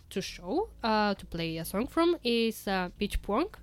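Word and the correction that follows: Bích Phương: Do I pronounce that right? – Yep, Big Foon. – Big Foon. – Yeah. – That was so off. Like, my pronunciation was so off. – But you tried, though. Don't worry about it. – Big Puong. – Yeah, Big Foon.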